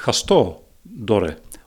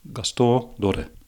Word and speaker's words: Gaston Dorren: Gaston Doren. When I say it that way, I can hear my mother speak. So I suppose, yes, that would be the Limburgish pronunciation. Gaston Doren.